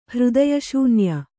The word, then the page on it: ಹೃದಯಶೂನ್ಯ: hřdaya śunya